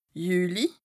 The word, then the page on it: Jüli: Bas-Rhin; Strasbourg